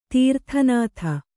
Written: ತಿರೋಧಾನತೆ

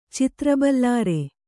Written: ಚಿತ್ರಬಲ್ಲಾರೆ

